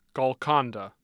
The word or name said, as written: Golconda